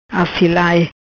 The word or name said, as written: Assilai